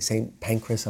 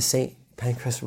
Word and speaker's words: Pancras: St. Pancras, I'm sure not pronouncing it in like the correct English way, but it sounds like pancreas, right?